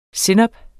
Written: sennep

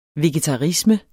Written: vegetarisme

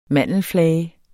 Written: mandelflage

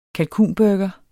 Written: kalkunburger

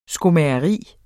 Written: skomageri

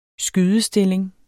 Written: skydestilling

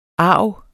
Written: arv

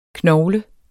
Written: knogle

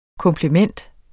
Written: komplement

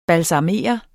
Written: BALSAMERE

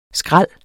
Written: skrald

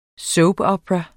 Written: soapopera

